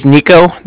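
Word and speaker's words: Niko: It was Nico. That's N-I-K-O.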